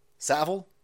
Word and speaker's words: Savile: Savile, I think it's Savile. Whenever I fuck up Jimmy Savile's name, which I just did right now, a whole bunch of Brits go, it's Savile, rhymes with gravel.